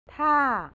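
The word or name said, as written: ท่า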